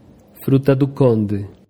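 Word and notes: fruta-do-conde: Brazilian Portuguese

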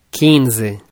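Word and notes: 15: Brazilian Portuguese

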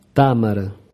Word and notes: tamara: Portugués Br

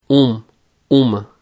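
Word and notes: 1: Brazilian Portuguese

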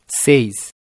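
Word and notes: 6: Brazilian Portuguese

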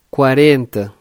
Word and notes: 40: Portugués Br

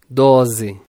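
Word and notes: 12: Brazilian Portuguese

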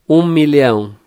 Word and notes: 1000000: Portugués Br

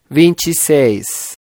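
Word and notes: 26: Brazilian Portuguese

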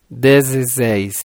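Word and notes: 16: Brazilian Portuguese

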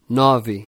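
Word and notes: 9: Portuguese; Brazilian Portuguese

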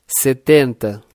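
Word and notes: 70: Portuguese; Brazilian Portuguese

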